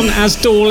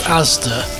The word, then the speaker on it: Asda: He was on Asdol FM. – Asdol. Oh, their in-house station. Yeah. – Yes. For those non-Bristolians out there, it's pronounced Asda.